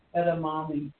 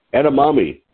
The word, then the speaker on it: edamame: How do you say that? Edamame? – Edamame. – Edamame. Okay. That's edamame.